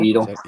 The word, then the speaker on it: Edel: I don't know if you ever seen, if you know Edil. – Yeah. – I don't know if I've said that right. So that's how you say it. – Yeah. I think it's Edil. Yeah. E-D-E-L. – Edil.